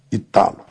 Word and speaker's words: Hytalo: Eu pronuncio como leio. H-Y-T-A-L-O. Italo.